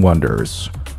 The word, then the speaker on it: wonder: iconic CN Tower, one of the seven wonders. Wonder, 注意是 wonder, 不是 wonder, okay, wonder 那是另外一个词，那是 W-A-N-D-E-R, 读作 wonder, 而这里读作 wonder, wonder, 注意不要有鼻音。Of the seven wonders of the modern world.